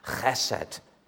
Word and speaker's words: Chesed: You need to recover from a chest infection to be able to pronounce it. Chesed.